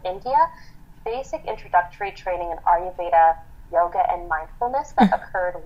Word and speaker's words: Ayurveda: You also have a background in Ayurveda. Did I say that right? Ayurveda? – Oh, yes.